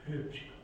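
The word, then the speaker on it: Huebsch: It's called Care for Our Common Home, by Bill, I'll try and pronounce it, is it Hoopsch or something? It's H-U-E-B-S-C-H. – Hoopsch.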